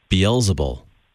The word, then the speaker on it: Beelzebul: Beelzebul.